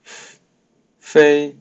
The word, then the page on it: 非: Mandarin Chinese; female